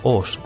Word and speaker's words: Auch: Este primer personaje nació cerca de Auch, se escribe A-U-C-H, Oss, en Francia.